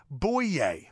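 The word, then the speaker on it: Bouyé: Needs R-O-A, a very funny show. A.J. Bouye's last name is pronounced Bouye. – Bouye. – Bouye. Just ask him.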